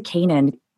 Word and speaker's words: Canaan: It's Egypt to Canaan. I'm saying that right, right? – Yeah, yeah.